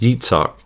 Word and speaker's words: Yitzhak: In English, we say Isaac. In Hebrew, though, it's actually pronounced Yitzhak.